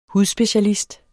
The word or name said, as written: hudspecialist